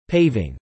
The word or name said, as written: paving